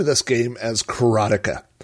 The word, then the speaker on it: Karateka: So just to clarify before we get started, I will be saying Broderbund, and I will be calling this game Karateka, which is the correct pronunciation. I hate to tell the guy that wrote the game, but that's how you say it.